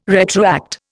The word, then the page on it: retroact: Indian